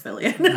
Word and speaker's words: Fillion: Fillion. – He's Canadian. It's Fillion.